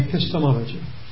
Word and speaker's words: epistemology: Epistemology.